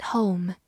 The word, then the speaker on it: home: home，h o m e，home，字母 o 发它本身的音 o，末尾的 e 不发音。 home。它是一个名词，表示家、住宅；形容词，家庭的，或者副词，回家。比如说，stay at home 就是待在家里。